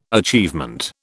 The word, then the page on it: achievement: British English